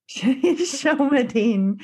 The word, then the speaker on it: Shelmerdine: I know people are wondering how Philip and, is it Shelmerdine? – Shelmerdine, who the name is taken from Virginia Woolf's Orlando.